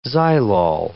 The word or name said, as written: xylol